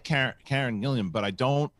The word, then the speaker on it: Karen: It's Karen. You're adding a syllable.